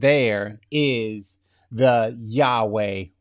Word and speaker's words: Yahweh: Um, so the actual pronunciation of how you say it, we we believe would be Yahweh.